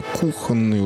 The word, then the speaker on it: кухонные: В слове «кухонные ударения на «у»» пишет Розенталь.